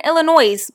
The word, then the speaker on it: Illinois: Just like me, when I went to Illinois, I was like, oh yeah, I live in Illinois. People looked at me like, drop the S. Since when is there a silent S in the English language? – Or what about when we drove through Arkansas?